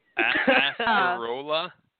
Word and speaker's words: acerola: So, acerola is how you say. – Oh, I'm going to fuck that one up. Acerola? – Yeah, that's how you say it, but it's the one that's A-C-E-R-O-L-A. It's acerola.